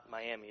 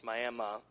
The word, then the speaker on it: Miami: It's spelled like Miami, but it's not Miami, it's Miami Miami Indians, Miami University in Ohio.